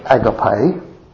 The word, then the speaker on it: agape: When the Bible talks about the word love, the word it uses is a Greek word commonly pronounced as agape, which means a self-sacrificing love.